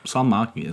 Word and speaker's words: Salmiakki: We'll be having a seminary classmate of mine on our episode next month, and he suggested that we get, I'm probably going to say it wrong, Salmiakki. – Salmiakki, it sounds good. Salmiakki, sounds about right.